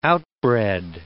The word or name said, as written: outbred